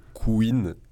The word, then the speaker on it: Couine: À la base, on s'appelait Queen. Et j'ai fait un gros effort pour bien le prononcer. – Comment tu l'écris ? – C-O-U-I-N-E, comme un cuinement.